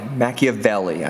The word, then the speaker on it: Machiavelli: Machiavelli, I believe, is the proper pronunciation.